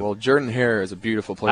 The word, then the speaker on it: Jordan-Hare: We had no Jordan-Hare.